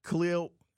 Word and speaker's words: Khalil: We do gush about Khalil, and I'm not even saying it right. Khalil Mack, he said that this week. His name is pronounced Khalil, which again goes into my whole thing that he might be of Krypton, but whatever.